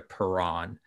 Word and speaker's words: Perron: So I kind of anglicized it back to Perron just for simplicity.